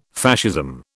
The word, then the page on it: Fascism: British English